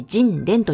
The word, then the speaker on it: 神田: その第 1 弾のお酒は、熊本県花の花酒造の神殿、神田と書いて神殿と読みます。